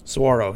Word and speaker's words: Saguaro: Did I say that right? Saguaro? – Saguaro, yeah.